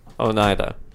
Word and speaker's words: Oneida: How do you say it? – Oneida. – There you go.